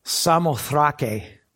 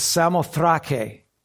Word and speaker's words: Samothrake: And so they're setting forth, they set sail up to, it's actually pronounced Samothrake, okay? We would pronounce it how? Yeah. So that's how they pronounce it. It's Samothrake, and it's Macedonia is how they pronounce it. So if you want to impress your friends, learn how the Greeks say it.